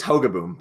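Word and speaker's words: Hogeboom: So Gary Hodgeboom, I don't know. Did I pronounce that right? – I think it's Hogaboom, but I'm like, Hogaboom.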